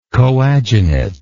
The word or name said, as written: coadunate